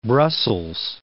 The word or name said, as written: Brussels